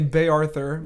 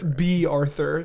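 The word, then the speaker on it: Bea Arthur: Yeah, I always say it wrong because I say Bay Arthur, but it's B Arthur, so it's B.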